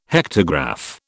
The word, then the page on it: hectograph: British English